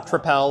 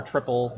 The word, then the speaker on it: Tripel: Tripel or Triple, depending on how you want to pronounce that.